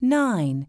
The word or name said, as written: 9